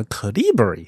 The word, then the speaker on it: Calibri: 他就宣布要在美国政府的公文当中废除使用一个叫做 Calibri 嘛的这样一个字体，好像这个字的，我后来去查了一下音标的话，重音好像在第二个音节，应该念叫 calibri。